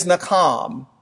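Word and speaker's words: nakam (נחם): The first Hebrew word that's used for repentance is nakam. You would spell it N-A-K-H-A-M. Nakham. I'm having ham for lunch, so ham's on my mind. But nakam is how you would say it.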